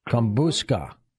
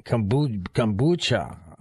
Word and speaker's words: kombucha: Kombuska. – Kombuska. What is that? – Kombucha.